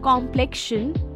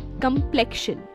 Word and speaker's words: complexion: And immediately I had to pause and say, wait a minute, it's not complexion, but complexion. Yes, the stress is on the second syllable and the O in the first is pronounced like a A in the China. Isn't English fun like that? To break it down, come isn't pronounced like complain or complete. Instead, it's softer.